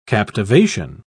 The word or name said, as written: captivation